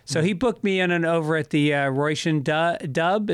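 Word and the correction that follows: Róisín Dubh: Is that how you say it? Roisin Dub? – So it's the Roisin Dove. It translates as the Black Rose.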